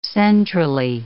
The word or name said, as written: centrally